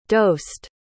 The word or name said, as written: dosed